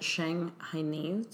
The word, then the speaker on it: Shanghainese: I would really love to dive deeper into, because you said that Shanghainese, did I say that correctly? – Yeah.